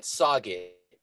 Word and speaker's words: Sauget: We call it Sauget. And that's because that's how it's spelled. It's with the French way where they put in 7 letters that are silent. – S-A-U-G-E-T is the spelling of Sauget.